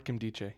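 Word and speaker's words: Nkemdiche: Is that how you say it? I always forget. – Robert Kemdiche, yeah.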